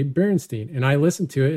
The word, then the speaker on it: Berenstain: They always pronounced it sort of ambiguously. It was Berenstain. That you don't enunciate and you don't put the emphasis on that last syllable.